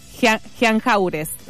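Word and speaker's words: Jean Jaurès: ¿Cómo se dice esa calle? – ¿Jean Lloré está bien o no? – Jean Lloré, sí. No, está bien. Yo porque me acordaba que yo la buscaba. – Jean Jaures.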